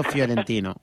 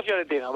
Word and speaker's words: fiorentino: Calcio hazlo. Histórico fiorentino. Hombre, pronunciación italiana. – Calcio histórico fiorentino, vale.